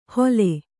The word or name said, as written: ಹೊಲೆ